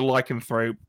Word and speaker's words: Lycanthrope: Lycanthrope, yeah.